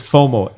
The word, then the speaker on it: FOMO: So it's just pronounced it as FOMO, F-O-M-O. So people don't think we're saying something else.